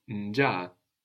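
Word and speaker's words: njaa: Because you pronounce both. – I pronounced both, which means the accent was on one of the A's, nja, rather than unja.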